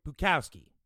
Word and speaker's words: Bukowski: If anyone's familiar with Charles Bukowski, am I saying his name right? I don't even know. Yeah, it's right. Bukowski.